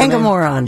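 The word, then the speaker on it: Engoron: Yesterday, the civil fraud trial judge, our buddy, the great, Engamoron, I believe is the way you would pronounce that. Engamoron is the Engamoron. – Name? – Yeah.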